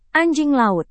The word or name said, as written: anjing laut